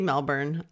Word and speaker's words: Melbourne: I'm going to say Melbourne. I can't. – No, that's how you say it.